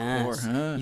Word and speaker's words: Coruscant: Coruscant. – You said it right this time.